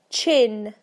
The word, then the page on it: chin: British English